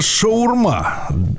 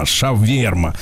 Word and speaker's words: шаверма: И пишется это на тамошних языках как шаварма. – А, шаварма. – А шаурма а это когда вот англиф, англифицированные угу. граждане, уа, как пытаются делать, да, вот языком своим, шаварма, шаверма. Шаверма надо говорить.